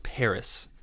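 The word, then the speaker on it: Paras: P A R A S. I believe it's pronounced Paris.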